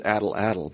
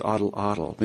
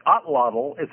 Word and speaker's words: atlatl: So you maybe you could explain a little bit I always pronounced it addle-addle, but it's oddle-oddle. Maybe you could explain what that is for those who don't know. – Okay. So it's a spear thrower. In fact, otlottle. So it's pronounced, even though it's two, it's A-T-L-A-T-L. It's pronounced as if it were A-T-L-A-T-L. – Okay. – It's actually an Aztec word.